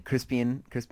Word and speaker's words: Crispin: And then Crispin. I don't know why I keep saying Crispian. – Because it's spelled with an A, but it doesn't need it.